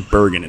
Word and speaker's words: Burgin: How did you end up with such a, you know, straight-laced, almost Canadian name, Kevin Burgin? It's spelled B-U-R-G-I-N, which is German.